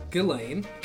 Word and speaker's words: Ghislaine: I love how you refuse to say her name correctly. – Ghislaine? – It's Ghislaine. – Ghislaine? – It's Ghislaine. – Oh, it's Ghislaine. Okay.